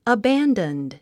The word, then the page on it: abandoned: əbǽndənd; アヴェンダンド